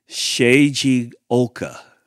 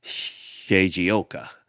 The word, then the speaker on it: Shigeoka: I spelled it phonetically for myself so that I could try to say it right. I think it's Shiji Oka. And it's spelled Shigeoka. Shigeoka, I think is how you say that.